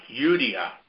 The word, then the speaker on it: Judah: Now, Judah is pronounced Yudia.